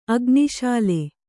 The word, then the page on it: ಅಗ್ನಿಶಾಲೆ: Kannada